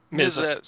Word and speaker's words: MZA: How do you pronounce these three-letter names? Like, RZA is RZA, right? What's right. MZA? MZA? – MZA. So MZA.